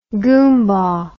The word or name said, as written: goombah